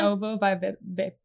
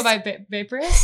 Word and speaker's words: ovoviviparous: How do you say it again? – Ovoviviparous. Now I can't do it. Ovoviviparous.